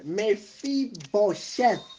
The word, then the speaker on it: Mephibosheth: Mephibosheth.